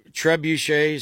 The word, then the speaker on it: trebuchet: The History Channel was doing biological warfare that literally goes back to the trebuchet.